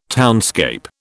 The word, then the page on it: townscape: British English